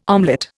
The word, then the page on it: omelette: Indian